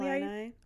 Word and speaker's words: Illini: According to the college paper, Daily Illinois, I think it's maybe Illini. Oh, is that how you pronounce it? – Yeah.